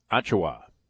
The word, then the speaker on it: Achiuwa: Achiuwa.